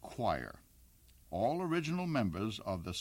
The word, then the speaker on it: Quire: A group known as the Choir. That's spelled, by the way, Q-U-I-R-E.